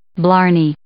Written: blarney

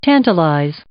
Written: tantalize

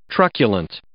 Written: truculent

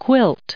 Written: quilt

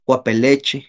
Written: kuapelechi

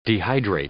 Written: dehydrate

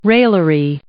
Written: raillery

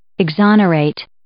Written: exonerate

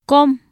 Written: kom

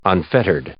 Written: unfettered

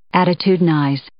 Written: attitudinize

